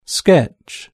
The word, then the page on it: sketch: British English